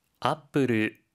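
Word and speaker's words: 아플 (アップル): 하이, 사과는 영어 애플의 일본어 발음인 아플이라고 하는데요. 회사명의 경우에도 동일하게 발음하니 알고 계시면 좋을 것 같습니다.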